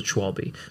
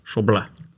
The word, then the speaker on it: Schwalbe: And a German guy messaged us on Instagram, and he told us it's Schwalbe.